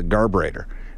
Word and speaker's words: garburator: And she was like, don't make sure no spoons go down the garburetta, right? And one of us was like, it's actually called a garburator. And she goes, well, whatever. I think garburetta is the British pronunciation.